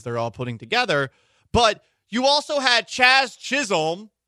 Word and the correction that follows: Jazz Chisholm: Jazz Chisholm.